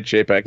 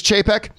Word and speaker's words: Chapek: Now, this quote comes to us from the new CEO of Disney, Bob Chapek, or Chapek, or however you pronounce Bob's last name. – No, you were right, Chapek. – It's Chapek.